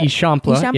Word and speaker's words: Eixample: Eixample, is that how you say it? Eixample, yeah.